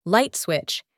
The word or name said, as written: light switch